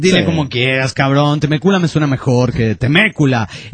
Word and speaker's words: Temecula: La ciudad de donde escribe el Poli Chafa no se pronuncia Temecula, sino Temécula. – Temécula.